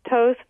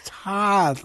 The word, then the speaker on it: Toth: So, and I should, your last name is spelled T-O-T-H. – Yeah. It's actually pronounced Toth, but so many people struggle with pronouncing it and spelling it. But Toth is okay, too.